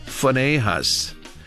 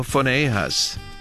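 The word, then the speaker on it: Phinehas: 이렇게 발음합니다. 퍼네이하스 PH로 발음을 해요. 퍼네이하스 이렇게 발음하시고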